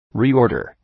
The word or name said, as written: reorder